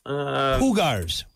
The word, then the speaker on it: Jaguars: Hoogars. Hoogars is what it is. Soft J. Soft J.